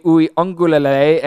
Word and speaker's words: Uiagalelei: I'm glad that I'm not the only person that calls him DJU because I believe that the pronunciation is DJ Uiagalelei.